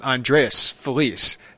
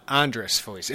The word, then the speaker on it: Andreas Felice: So I feel like I need to call him Andreas Felice, not Andres Felice. That's how he pronounces it.